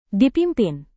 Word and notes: dipimpin: indonesian

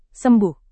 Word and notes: sembuh: Indonesian

